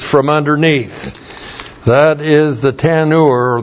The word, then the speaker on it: tanur: Well, in verse 4, we have the word oven, and the word for oven in the Hebrew is tanur.